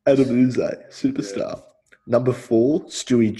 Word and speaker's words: Yze: Number five for me, I've got Adam Uze. – Oh, I thought about him, but I didn't. – Sorry, who? – Adam Uze. – How is that? – His name is spelled YZE as a last name.